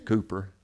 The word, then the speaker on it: Cowper: He spells it C-O-W-P-E-R. We'd pronounce that Cowper. But good British folk, he's Cooper.